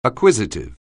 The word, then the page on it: acquisitive: anglais